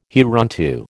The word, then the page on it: hereunto: American English